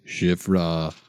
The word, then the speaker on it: Shiphrah: The king of Egypt said to the Hebrew midwives, whose names were Shiphrah, I think I said that right, and Puah. I need to say that like Stephen B. Stevens. – Shiphrah and Puah.